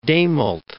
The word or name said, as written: Dehmelt